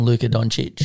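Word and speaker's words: Luka Dončić: I'm going Luka Doncic.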